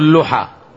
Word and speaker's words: اللحى: اثنين: وفروا اللحى. تراه بضم الحاء، بضم اللام، بضم اللام، لأن اللحى لحاء الشجر. أي نعم. والصحيح أنها بالضم.